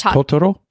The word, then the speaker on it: Totoro: Did I say that right? – I think it's Totoro.